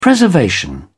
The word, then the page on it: preservation: British English